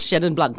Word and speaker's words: Chenin Blanc: We don't do the French thing, okay? We're South African. We just say it like it is, Chenin Blanc.